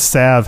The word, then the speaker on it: salve: This card is probably the most mispronounced card in Alpha, because for those of you who aren't noticing it, the word salve has a silent L. It's pronounced without the L.